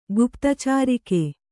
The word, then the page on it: ಗುಪ್ತಚಾರಿಕೆ: guptacārike